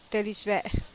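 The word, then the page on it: tedixbeeh: Teotitlán del Valle Zapotec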